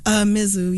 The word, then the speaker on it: Mizzou: Very clearly, I'm giving it to Jonathan Butler and all of the folks at Mizzou. Is that how they say it? – Mizzou, yeah. Mizzou.